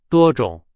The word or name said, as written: 多种